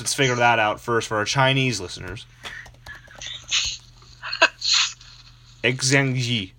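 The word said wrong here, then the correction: Xingqi yi (星期一): Xingqi yi.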